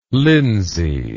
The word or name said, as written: linsey